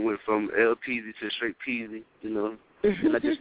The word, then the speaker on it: Peze: I'm Peezy. Yeah, and it's pronounced just the same way it's spelled, P-E-Z-E.